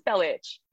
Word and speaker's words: Belic: And I was like, can you imagine the fucking caucasity of this man trying to tell me how to pronounce my name? – My name. – Right? And my dad was like, oh, I mean, like, it is Belic.